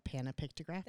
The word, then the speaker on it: panopictographist: So he's a panopictographist. – Yes, I am also. – Did I say that right? – A panopictographist.